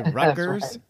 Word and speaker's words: Rutgers: So when you say Rutgers, you do actually pronounce the T. It's just sort of blended. – But not the R? – You don't say Rutgers? – It's Rutgers.